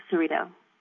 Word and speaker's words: Cerrito: Many people have trouble pronouncing Cerrito. I tell them it rhymes with burrito.